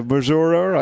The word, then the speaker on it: Missouri: As they say there, Missouri, I think is the pronunciation.